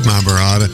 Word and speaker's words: Mahabharata: How about the Mahabharata? Do I say that correctly? – Mahabharata. Yeah, but